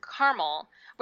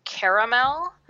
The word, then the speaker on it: caramel: So I pronounce the word caramel, which is how it's typically pronounced on the West Coast, if mm-hmm. you look at language dialect maps. And but people on the East Coast absolutely believe it should be caramel, mm-hmm. three syllables, or caramel.